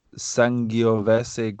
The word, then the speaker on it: Sangiovese: A dry red wine made from Sangiovese grape. I said that exactly how it's pronounced.